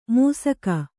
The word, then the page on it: ಮೂಸಕ: kannada